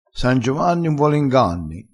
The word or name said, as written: san giovanni